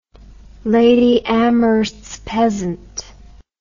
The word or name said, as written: Lady Amherst's pheasant